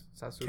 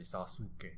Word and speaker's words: Sasuke: So in Japanese, you don't really quite enunciate the U like you would in Spanish. Like you wouldn't say Sasuke.